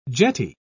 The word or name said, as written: jetty